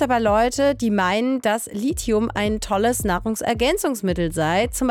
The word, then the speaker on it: Lithium: Ja, Leute, das wird Lithium, nicht Lithium ausgesprochen, dass wir das schon mal am Anfang hier geklärt hätten.